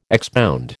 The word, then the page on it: expound: American English